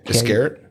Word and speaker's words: Iscariot: Iscariot? – Yeah.